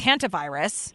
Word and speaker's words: hantavirus: Let's start with hantavirus. Am I even saying it correctly? – Hanta. You are. And I think you know, it's pronounced both ways.